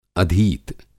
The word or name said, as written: अधीत